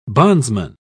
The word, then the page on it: bondsman: British English